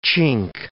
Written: chink

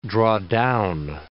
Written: draw down

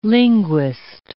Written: linguist

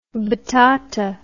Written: batata

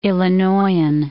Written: Illinoisan

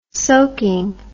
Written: soaking

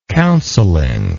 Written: counseling

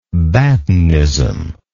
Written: batinism

